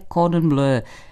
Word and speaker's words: Cordon bleu: Často špatný zápis a s tím spojená výslovnost jsou zapříčiněny podobností anglického a francouzského výrazu pro modrou barvu blue a bleu. Výše představené slovo však pochází z francouzštiny, tudíž jeho správná francouzská výslovnost bude Cordon bleu.